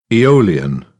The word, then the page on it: eolian: British English; əʊ